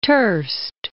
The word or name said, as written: Trst